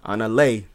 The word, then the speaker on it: Annalé: Annalé, that's how you pronounce it? – Annalé, yep.